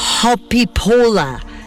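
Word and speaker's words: Hoppípolla: Y además, la canción se llama Hopipola, que se escribe Hopipolla, y que quiere decir en su idioma, en islandés, saltando los charcos, o saltando en charcos.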